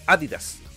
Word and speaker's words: Adidas: Y Adidas es Adidas, porque también es alemana.